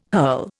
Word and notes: hurl: Indian

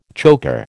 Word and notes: choker: American English

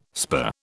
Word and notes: spur: British English

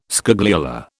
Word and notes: scagliola: British English

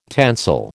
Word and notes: tensile: American English

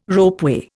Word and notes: ropeway: Indian

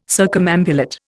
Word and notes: circumambulate: Indian

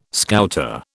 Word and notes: Scouter: British English

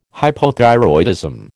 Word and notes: Hypothyroidism: American English

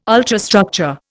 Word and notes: ultrastructure: Indian

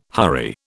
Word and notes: hurry: British English

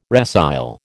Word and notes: resile: American English

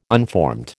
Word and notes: unformed: American English

